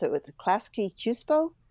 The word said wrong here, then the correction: Klasky Csupo: I think it's Klasky Csupo. – Yeah, that's it.